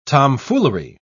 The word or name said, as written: tomfoolery